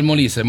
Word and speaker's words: Molise: Här har vi Magical Molise, The Italian Secret. Var det rätt uttalat nu? – Alltså, exakt, Magical Molise.